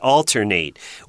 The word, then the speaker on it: alternate: And when it's a verb, it's a long A sound at the end, alternate,